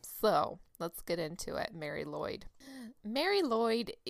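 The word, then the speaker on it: Mari Lwyd: Now, that's how it's pronounced. It's not spelled the Mary Lloyd. I'm going to tell you how it's spelled right now, because it's okay. Welsh, and it's like all the consonants. Mary is M-A-R-I. Pretty mm-hmm. standard. – Mary, yeah. – Lloyd is L-W-Y-D.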